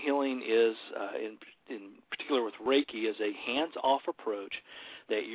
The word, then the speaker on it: Reiki: Hey, I hope I'm pronouncing this right, but you sent me some information in regards to, I believe it would be pronounced Reiki Energy Healing? – Yeah, Reiki Energy Healing.